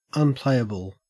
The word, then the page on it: unplayable: Australian English